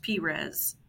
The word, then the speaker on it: Perez: And so looks like Perez, pronounced Perez. – Yes, Perez.